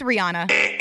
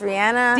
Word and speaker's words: Rihanna: It's Rihanna.